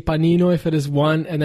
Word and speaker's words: panino: So if you like panini, it's actually panino.